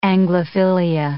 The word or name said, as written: Anglophilia